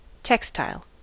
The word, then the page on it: textile: American English